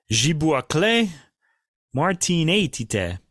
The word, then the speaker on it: Žibuoklė Martinaitytė: And uh, I'm going to sort of slightly anglicize this Martinaitytė. Okay, I'm not going to kind of say it like a Lithuanian.